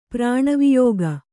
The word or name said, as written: ಪ್ರಾಣವಿಯೋಗ